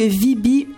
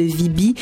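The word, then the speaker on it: Veeby: Alors, on poursuit en musique avec nul autre que Vibi avec son titre Mangue. Si vous tombez en amour avec la voix de Vibi, je vous informe qu'elle est en spectacle samedi le 18 avril prochain à la la Place des Arts.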